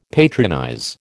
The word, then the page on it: patronize: American English